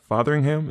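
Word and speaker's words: Fotheringham: Uh, is it Fathering Fatheringham? Is that his? Is that how you pronounce his name? – Yeah. So he's like five times WCMX men's world champion now, which is absolutely insane.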